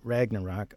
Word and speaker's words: Ragnarok: This is called Ragnarok. I hope I'm pronouncing that right. Ragnarok, R-A-G-N-A-R-O-K.